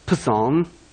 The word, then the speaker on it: Psalm: If you're hooked on phonics, how would you pronounce that? Psalm. The P sign.